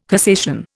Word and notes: cassation: Indian

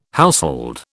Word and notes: household: British English

